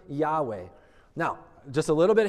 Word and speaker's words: Yahweh: It's the word Yahweh. And Yahweh, maybe you've never heard that word before, but that is, um, at least in our English language, how we would pronounce the proper name of God.